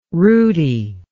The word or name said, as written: rooti